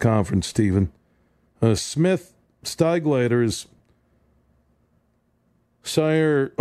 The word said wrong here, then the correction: Sayre: Oklahoma. Did I say that right? Who knows? And I went to OU, did radio in Oklahoma for 4 years, and I may have just jacked Smith's hometown. Sayre.